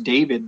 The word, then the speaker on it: Deivid: This new guy, his name, I think it's just pronounced David, but it's spelled D-E-I-V-I-D.